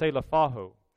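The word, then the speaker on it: selephaho (ψηλαφάω): Well, the Greek word that Paul actually spoke is pronounced selephaho, and it means simply to handle or to touch or to feel.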